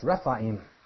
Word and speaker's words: Rephaim: This is a word called, it's pronounced Rephaim.